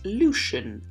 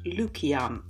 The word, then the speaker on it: Lucian: Traditionally, because his name in English is written with a C, it would have been more appropriate to pronounce the name as Lucian and not Lukian, as I'm doing. But his name is not a Latinized version of a Greek name like Lucifer, so I am pronouncing the name as a Greek person pronounces it.